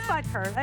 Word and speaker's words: Butker: Right, yeah, it is Butker. I said it right.